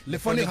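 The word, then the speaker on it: Le Phonic: Before we went to the adverts, we were speaking to, is it Lefonic? – Lefonic. – Lefonic, how do you spell it? – It's L-E space P-H-O-N-I-C. – Or N-I-C, Lefonic with a C, no? – With a C, my friend.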